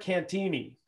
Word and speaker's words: Cantieni: So why not get things cranking up with the Seattle Mariners strength coach, Derek Cantieni.